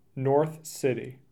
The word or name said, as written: North City